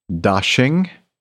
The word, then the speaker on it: Daxing: Beijing's Daxing, D-A-X-I-N-G airport, P-K-X, excuse me.